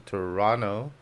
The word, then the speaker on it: Toronto: Hay que saber que Toronto, los canadienses no dicen no pronuncian la T, es Toronto.